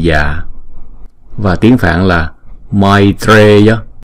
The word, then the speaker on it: maitreya: Câu này chúng ta đọc là maitreya, maitreya. Chữ này không phải là may, mà không phải là mai, mà là may, ai. Chữ A, Y là một nguyên âm ha.